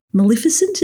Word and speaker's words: Maleficent: fabulous Maleficent, is that how you say it? – That's how I would say it. – Excellent. – If I could say it properly.